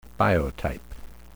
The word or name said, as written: biotype